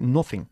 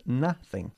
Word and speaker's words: nothing: Y una vez más, la pronunciación de esta palabra tan común suele presentar problemas para los españoles, porque dicen siempre nothing, es nothing. – Ok. – Una vez más, bajando la mandíbula, ¿ok? – Vale.